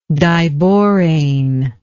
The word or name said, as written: diborane